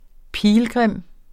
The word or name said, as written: pilgrim